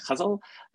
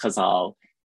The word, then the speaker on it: ghazal: The ghazal also has different pronunciations. So I learned the ghazal from Aga Shahar Ali, and he insisted that one had to say it ghazal, but I've also spoken to Arabic speakers who say that it is pronounced ghazal in Arabic. So depending on where your ghazal comes from, you can say it in different ways.